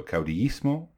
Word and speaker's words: caudillismo: So it's pronounced caudillismo, and the leader is a caudillo. So caudillismo and caudillo.